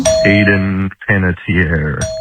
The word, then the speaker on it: Hayden Panettiere: Hayden Panettiere.